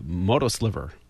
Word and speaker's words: MOTOSLVR: It was created by Motorola, and it's called the Moto Sliver. But they spell it MOTOSLVR.